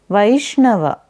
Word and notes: Vaishnava: Sanskrit

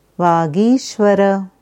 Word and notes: Vagishvara: Sanskrit

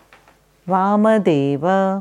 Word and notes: Vamdev: Sanskrit

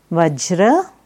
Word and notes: Vajra: Sanskrit

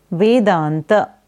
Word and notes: Vedanta: Sanskrit

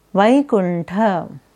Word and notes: Vaikuntha: Sanskrit

